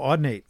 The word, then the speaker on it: Audinate: Or I think the analog, the AudioNet, I make sure I'm pronouncing it right. Audio, this stuff here, sorry. – Oh, Audinate, yeah.